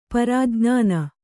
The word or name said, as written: ಪ್ರಜ್ಞಾನಿ